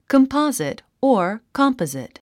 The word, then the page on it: composite: kəmpάzit; コンポォジット